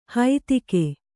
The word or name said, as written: ಹೈತಿಗೆ